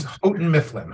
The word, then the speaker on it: Houghton Mifflin: It's, it's Houghton Mifflin. Houghton Mifflin. – Houghton Mifflin. Okay, Houghton Mifflin.